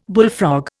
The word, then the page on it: bullfrog: Indian